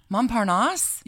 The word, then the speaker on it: Montparnasse: Am I saying that right? – Montparnasse? – Yes.